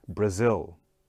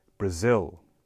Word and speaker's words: Brazil: So again, two syllables. Listen to me one more time. Brazil. Brazil. Okay? Not so hard this time. Again, the second syllable gets the stress, zil, okay, that Brazil.